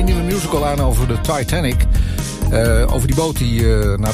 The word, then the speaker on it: Titanic: Nou, het is Titanic. Het is zeg maar hoe Celine het op zijn Engels met haar Franse accent zou zeggen. Dus Titanic.